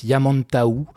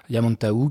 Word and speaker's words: Yamantau: alors on prononce Yamantaou, Yamantaou qui est en fait un sommet du massif de l'Oural en Russie.